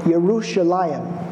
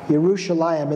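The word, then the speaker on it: Yerushalayim (ירושלים): In Hebrew, you might pronounce the name Jerusalem. You might say Yerushalayim. Yerushalayim.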